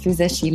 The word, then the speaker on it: Füzesi: Okay, also mein Name ist Füseshi Linder. Ähm, das können außer die Ungarn vielleicht noch die Finnen, die anderen Nationen nicht unbedingt äh, komplett korrekt aussprechen.